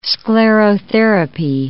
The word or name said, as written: sclerotherapy